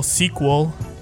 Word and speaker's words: CQQL: I got some news that is related to my label Sequel. That's spelled C-Q-Q-L.